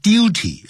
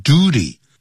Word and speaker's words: duty: 不要念成 duty, 要念 duty.